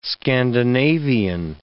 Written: Scandinavian

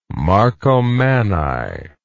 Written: marcomanni

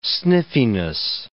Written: sniffiness

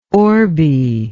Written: orby

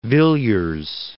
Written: Villiers

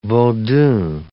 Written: vodun

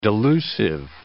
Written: delusive

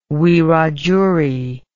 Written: wiradjuri

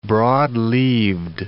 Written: broad-leaved

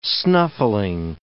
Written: snuffling

